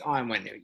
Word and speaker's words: Onwenu: Like, no, I thought, is it Onwenu? Is that how you say it? – I think it's Onwenu, yes.